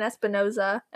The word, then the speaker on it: Espinosa: And Eden Espinosa, am I saying that right? – Yes.